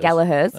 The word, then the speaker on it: Gallagher: The Gallaghers. – Gallaghers. – Yeah. – That's correct. She's Irish, and she says that is the correct pronunciation.